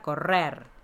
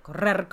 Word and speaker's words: correr: Todas tienen la dificultad de pronunciación, que es la doble R. ¿Qué recomiendas para mejorar la pronunciación de la R? – Mirarte al espejo y decir muchas veces la palabra correr. Correr, correr, correr, correr, correr, correr. Y vas a ver cómo logras pronunciar la R.